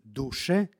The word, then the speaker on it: duše: Tako da vokativ od imenice duh glasi duše sa kratkim akcentom, dok vokativ imenice duša glasi blagoslovi duše moja gospoda sa dugosilaznim akcentom.